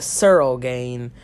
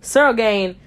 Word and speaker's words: Ciryl Gane: Man, as a huge fan of, uh, why did I say Circle Gain? I meant Ciryl Gane.